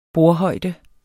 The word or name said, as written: bordhøjde